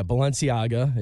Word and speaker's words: Balenciaga: So, yeah, Balenciaga, and you did pronounce that correctly.